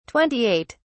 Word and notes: 28: British English

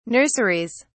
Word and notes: nurseries: British English

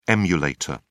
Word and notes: emulator: British English

